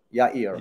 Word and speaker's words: Yair: My name is better pronounced when you say yeah and ear. Yair.